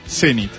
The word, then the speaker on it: zenit: Son válidas las dos acentuaciones para esta palabra, aunque es preferible la forma aguda Zenit.